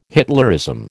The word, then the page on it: Hitlerism: American English